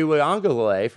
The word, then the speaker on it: Uiagalelei: DJ Uiagalelei from Clemson, he's like maybe the sixth or seventh best quarterback in the conference.